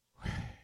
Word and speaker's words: Yahweh: And so what I've learned recently is that it's actually, it can be the sound of your breathing. So Yahweh.